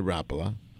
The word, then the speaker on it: Rapala: Uh, we're, we're slowly, slowly getting uh, Americans to say the name of that lure company right, which is Rapala. And actually, it's that guttural northeastern European that uh, Americans can't even really pronounce.